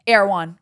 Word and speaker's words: Erewhon: Ooh, no, it's Erewhon or? – Erewhon. – Erewhon. – And don't say it wrong. – Okay, Erewhon, not Erewhon. Erewhon.